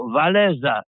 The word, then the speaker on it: Wałęsa: Francuzi nazwisko Trybuna Robotniczego wymawiają Waleza, co dla ucha polskiego brzmi prawie jak Walezy.